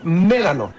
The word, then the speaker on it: Médano: Mejor dicho, Médano. – Ah. – Dije, voy a ir. – Sí, porque si está. – Dije, ay. – Tiene acento en la E. Es Médano, no Médano.